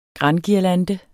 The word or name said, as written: granguirlande